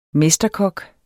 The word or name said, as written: mesterkok